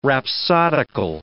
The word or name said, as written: rhapsodical